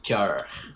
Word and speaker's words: Cœur: You pronounce it Cœur. – Cœur? Cœur de Pirate? – Use your throat. Cœur. – Cœur de Pirate. Ah, god damn it. – You can roll your R.